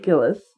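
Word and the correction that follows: Gittes: Giddis, excuse me.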